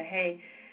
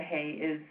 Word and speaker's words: He: He, the letter He, is just breath, right? The way you pronounce it is just a... If there's no vowel under it, it doesn't have a sound. Just is an exhalation.